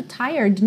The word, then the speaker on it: tired: Atenção que esse E do tired não é pronunciado. Você tem esse R enrolado da porta com a perna esquerda, um pouquinho prolongado. And all my friends are tired.